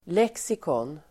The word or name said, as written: lexikon